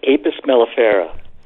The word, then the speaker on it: Apis mellifera: And it's pronounced Apis mellifera. – I mean, you don't need to talk down to me. I just needed you to say the name. Apis. – It's informational. – It's Latin.